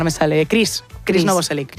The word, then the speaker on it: Novoselic: Pero Novoselic, ¿no? Lo he dicho bien, ¿no? – Es como Thor. – O sea, Novoselic sí, el, ah, vale.